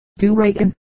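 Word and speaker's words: Doughoregan: the whole being pronounced, as with a slight brogue, do reckon, the accent falling on the middle syllable.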